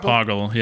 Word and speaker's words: Poggle: And I'm Ben Fowler reminding you, Pogel the Lesser cometh. – You can say his name right, at least. It's Poggle, isn't it? Isn't it Poggle? – Poggle, yeah.